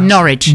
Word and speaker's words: Norwich: How's it pronounced? – Norwich. – Norwich. – Norwich. Norwich. Norwich. – It's not porridge, is it? – No, but the English just like to try and trip you up with that.